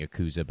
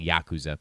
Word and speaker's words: Yakuza: My whole life I've been saying Yakuza, but it's actually Yakuza.